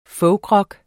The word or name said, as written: folkrock